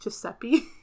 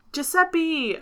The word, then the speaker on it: Giuseppe: The first one is, and I mean, I think this is pronounced Giuseppe. – Yep. – Giuseppe!